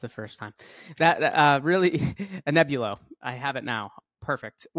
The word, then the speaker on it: Anebulo: Joining me today is Simon Allen, the CEO at Anebulo Pharmaceuticals. Did I say that right, Simon? – It's not an easy one to pronounce. Anebulo Pharmaceuticals, but Anebulo. Yeah, Anebulo.